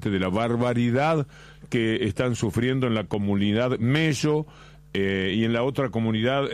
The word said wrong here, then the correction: Melo: Es Melo y Kinsikeu. Se escribe de una forma y sí. se pronuncia de otra. – Bueno, nosotros somos Melo con una sola L.